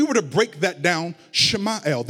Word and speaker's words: Samuel: When you say his name, though, if you were to pronounce his name in the Hebrew native tongue, it is actually pronounced Shemael, Shemael.